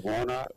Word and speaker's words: Warnock: Is that how you pronounce his name? – Yeah, Warnock.